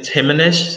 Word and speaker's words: Himanash: Yeah, so it's Himanash, so hi, man, is, so just like breaking it out into syllables. – And how do you say your last name? – Goel, G-O-E-L.